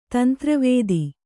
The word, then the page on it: ತಂತ್ರವೇದಿ: tantra vēdi